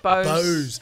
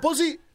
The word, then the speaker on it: Bose: Bose. – Is that how you say it? It's not Buzzy? – It's not Buzzy.